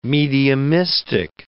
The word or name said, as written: mediumistic